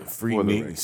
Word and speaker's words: Freaknik: Freaknik. See? It's just Freaknik. – Freaknik. Okay, yeah, that's how you say it.